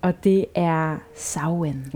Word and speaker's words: Samhain: Den staves jeg nemlig Samhain, men udtalt Samhain.